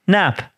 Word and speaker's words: Knapp: Kelleher and Knapp, yeah. – Knapp. Why put the K there? Like, this is what sickens my whole. It is unnecessary K.